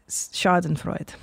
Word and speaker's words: Schadenfreude: Schadenfreude. Did I say that right? Chris? Schaden. Not Chris, sorry. – What? – Chad Wallen. – Whoa. The? – Sorry, oh, sorry. I got you guys. I misnamed you. – Whoa. – She just called you stupid, dude. – I intelligence shamed you by calling you Chris. – I think you're close enough on the pronunciation.